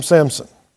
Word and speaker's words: Samson: Again, for my own benefit and the rest of us, there's no letter P in that word. Samson. We typically pronounce it with a P, right? Samson. Or that's very common, let me put it that way.